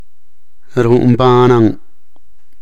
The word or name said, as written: ru’un banan